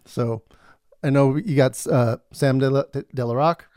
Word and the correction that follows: De La Rosa: Sam De La Rosa.